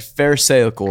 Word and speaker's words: pharisaical: Okay, said pharisaical wrong earlier. – Okay, and so then he then influenced me to say it incorrectly. – Yes, he did say pharmaceutical. So very similar to pharmaceutical. – Yeah. Okay. – Not to be confused.